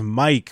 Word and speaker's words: Myq: Like, yeah, this comedian's name is Mike, but it's spelled M-Y-Q.